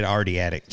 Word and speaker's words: Adriatic: I don't know that you pronounce that coastal region correctly. Is it Adriatic? – Like, oh, it is Adriatic. I said Adriatic.